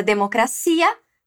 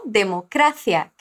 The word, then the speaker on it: democracia: Em português é democracia, si é a sílaba tônica. Em espanhol, democracia, quer dizer, cra é a sílaba tônica.